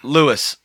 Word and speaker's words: Lewes: Lewis. It's spelled Lewes. Okay. I'm going with Lewes. – It's Lewis, Delaware.